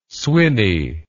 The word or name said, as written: swinney